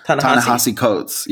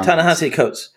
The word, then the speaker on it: Ta-Nehisi Coates: Ta-Nehisi Coates. Yeah. – Yeah, Ta-Nehisi Coates.